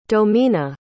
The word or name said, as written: domina